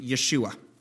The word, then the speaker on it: Yeshua: When they spoke Hebrew, they would have pronounced the name Yeshua.